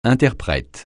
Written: interprète

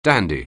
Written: dandy

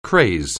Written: craze

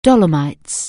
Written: Dolomites

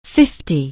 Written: fifty

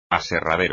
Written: aserradero

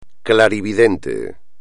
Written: clarividente

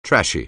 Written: trashy

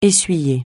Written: essuyer

